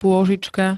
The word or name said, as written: pôžička